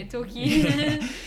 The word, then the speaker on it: Torquay: How do you pronounce that? Sorry. – Yeah, Torquay.